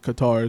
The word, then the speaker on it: Qatar: I was actually born in a country called, I would say Qatar, but it's called, you know, in English terms, it's Qatar is what it's pronounced.